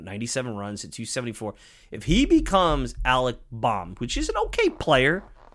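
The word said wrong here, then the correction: Bohm: Baum. Boehm. Alec Boehm. Boehm. Alec Boehm. Alec Boehm, not Baum.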